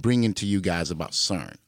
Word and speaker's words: CERN: So, it's pronounced CERN and it's spelled S-E-R-N. – C-E-R-N. Come on, motherfucker. – C-E-R-N.